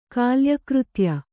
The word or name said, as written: ಕಾಲ್ಯಕೃತ್ಯ